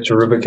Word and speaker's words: cherubic: And then you had mentioned the angels, and we have a cherubim hymn, or is that how you say it? – A cherubic hymn, yeah.